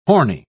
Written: horny

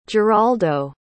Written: jeraldo

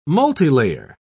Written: multilayer

